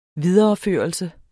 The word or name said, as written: videreførelse